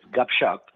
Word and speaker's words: Gupshup: How do you pronounce that? Gupshup? – Yes. Yeah, well, it's Gupshup, and by the way, it means chit-chat. It means chit-chat in Hindi.